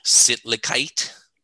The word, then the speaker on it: Sittlichkeit: So am I pronouncing this word correctly? Sittlichkeit? – Yes, that is correct.